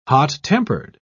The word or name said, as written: hot-tempered